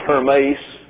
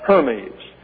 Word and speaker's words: Hermes: That's H-E-R-M-E-S. That's an eta down here, so it's Hermes. But you know him as Hermes, and that's spelled in the English H-E-R-M-E-S.